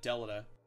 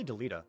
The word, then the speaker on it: Delita: how do you say Delita? – I say Delita.